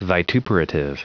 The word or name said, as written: vituperative